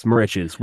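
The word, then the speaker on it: Moriches: So, want to talk about the song that you guys did a music video for, More Riches. Is that how you pronounce that? – Yes, More Riches, which a lot of publications are saying that's a road on Long Island.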